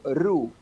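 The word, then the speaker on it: ro: No, te explico, ru. En noruego se escribe R-O, pero la O en noruego se pronuncia U, es remar. – Bien. – Remar. Por eso dicen ru, porque es remar.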